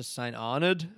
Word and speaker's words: St. Arnaud: A bunch of us went up to St. Arnaud, which is how I'm committing to saying it because that's how the locals say it, even though it's St. Arnaud, which to me is Arnaud because it's like French, whatever, but they say Arnaud.